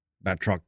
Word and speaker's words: Batroc: Batroc the Leaper. I like that they brought him back. – That's how you say it.